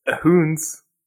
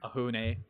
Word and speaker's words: Ahune: It's Ahoons. I think I said that right. Right, Dustin? – That's right, man. – Okay, good. – Ahune.